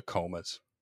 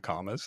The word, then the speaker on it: Comas: So the band in question is a band called the Comas. In my head, I like to mispronounce it as the Comas. – Oh. – Because that's funny to me. – It's a single M.